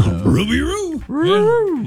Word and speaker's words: Rougarou: Well, we got the Baton Rouge Rougarou. Every time I say that, I kind of want to say it like Scooby-Doo. So. – Rougarou.